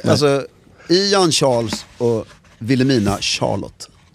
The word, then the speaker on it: Charlotte: Charlotte, ska man uttala det så också? – Ja, men, ja, för det är ju Charles, det är ju det engelska.